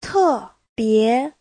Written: te4bie2

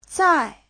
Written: zai4

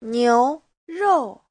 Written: niu2rou4

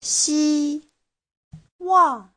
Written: xi1wang4

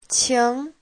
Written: qing2

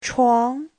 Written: chuang2

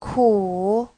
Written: ku3

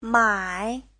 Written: mai3

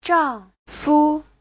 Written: zhang4fu5

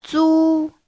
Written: zu1

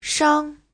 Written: shang5

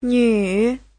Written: nv3

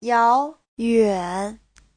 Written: yao2yuan3

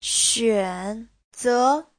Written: xuan3ze2

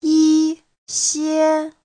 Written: yi1xie1

